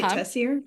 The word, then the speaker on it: Tessier: Is that how you say it? Tessier? – Mm-hmm.